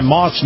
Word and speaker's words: Maus: And this portion of the program, my conversation with Colonel Jim Warshak, is brought to you by Moss Nissan. Now, Moss is spelled M-A-U-S.